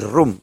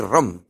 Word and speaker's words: ROM: Y por tanto no hay que decir ROM, hay que decir ROM. Sea en castellano o sea en inglés. En inglés la palabra habitación que tiene dos OEs se escribe ROM, se lee con U, room. Pero eso es habitación y lleva dos OEs. La ROM de los Android es con una O, y debería ser ROM.